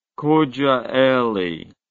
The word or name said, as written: Kocaeli